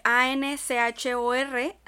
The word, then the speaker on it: Anchor: que se escribe A N C H O R, se lee Anchor.